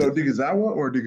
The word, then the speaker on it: Odighizuwa: Did I say that right? Odighizuwa? – Thank you guys for having me on. I appreciate you guys. – Odighizuwa. – First, did I get the name right? – Yeah. You said Odighizuwa. – Odighizuwa. – Okay, good. Perfect.